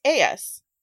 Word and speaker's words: Aeas: I will ask, how are you guys pronouncing Aeas? Maybe it's like that. I feel like the way that it's spaced out in the pronunciation, I automatically say A-U-S, which doesn't sound right. – Wait, what is that? Is that on the I would say it was. – It's just Aeas. – Yeah, Aeas. – Aeas. – Okay. – I think when I was reading it, I called her Ios.